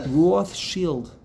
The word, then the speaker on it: Rothschild: Well, it's not child. – The right. – Shield. – Right. – Shield. – Right. – Right. Right. Right. Right. You spell it. There's an S in there. – Right shield. – That's a German Roth shield. Fascinating.